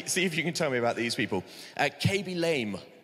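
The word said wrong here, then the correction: Khaby Lame: Carby. Okay, I said it wrong to start with, but that's fine because I'm not young, so that's all right. Okay, Carby Lame.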